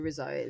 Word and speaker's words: RZA: is it RZA? How do you say that? – RZA.